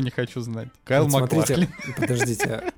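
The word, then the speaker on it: MacLachlan: Маклахлин, Маклахлин, Ч, там CH, это, это не, не читается как К.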